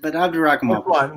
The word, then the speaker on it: Abdurakhimov: But Abdurakhimov.